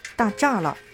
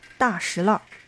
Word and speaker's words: 大栅栏: 那其实呢，大栅栏不念大栅栏，而是念作大石栏。老北京人说大石栏，只有初到北京的人才会照着字面的意思说，那叫大栅栏。之所以有大石栏的这种叫法，是为了区别西单的一条叫做大栅栏的胡同，而现在这条胡同的名字叫做中生胡同。